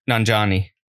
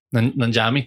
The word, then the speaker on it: Nanjiani: I don't know how to say his name. Selma Hayek. – Nanjiani. – Nanjiani, thank you.